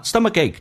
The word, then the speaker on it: stomachache: 那么 stomachache 这个单词，第一个 k 可以浊化成 g 这个发音 stomachache，最后一个 k 可以不用发音，但气流阻塞一下。